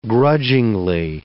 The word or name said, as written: grudgingly